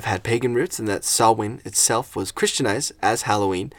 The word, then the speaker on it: Samhain: It's widely believed that many Halloween traditions originated from ancient Celtic harvest festivals, particularly the Gaelic festival Samhain. – It's pronounced Samhain.